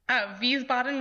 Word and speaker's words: Wiesbaden: Wiesbaden was correct.